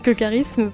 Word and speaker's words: charisme: et il s'avérait que charisme provienne du grec, et donc se prononce K par...